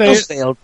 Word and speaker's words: Macclesfield: Ben from Macclesfield, England. Is that how you say it? – Macclesfield. Wow, okay.